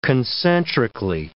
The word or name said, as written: concentrically